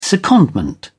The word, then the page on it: secondment: British English